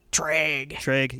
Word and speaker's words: Treag: Yeah, and it's spelled like T-R-A-E-G? – T-R-E-A-G, yeah. – Treg.